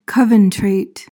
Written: coventrate

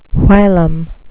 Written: whilom

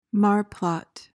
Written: marplot

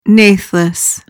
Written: natheless

